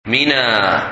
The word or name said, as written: Mina